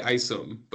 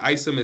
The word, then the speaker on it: Isom: Yeah, so both Instagram and Twitter, I'm at Eli Isom, but the Isom is spelled EYESUM, because a lot of people have a hard time pronouncing my last name. Yeah. So, I have to spell it out for them exactly how it sounds.